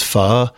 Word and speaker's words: pho: It looks like pho, but it's actually pronounced pho.